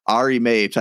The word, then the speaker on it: Ari Mapes: Ari Mapes. I hope I pronounced that. It's always the kids of like the people I have on that I think I pronounce wrong. But perfect. You nailed it.